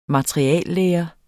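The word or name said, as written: materialelære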